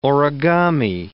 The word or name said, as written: origami